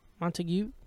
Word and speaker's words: Montague: It's north of like Montague. – Okay. – Montague. – Montague? – Silent G.